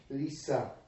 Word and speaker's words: Lyse: L-Y-S-E. – Huh? – Lisa. L-Y-S-E is a village in Poland.